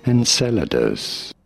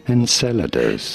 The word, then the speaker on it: Enceladus: Enceladus. – Do that again. Tell me that again. – This is the 6th largest moon of Saturn. How do you say it? Enceladus.